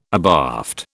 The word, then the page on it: abaft: British English